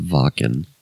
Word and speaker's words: Wacken: I think it's pronounced Wacken.